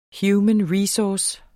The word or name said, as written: human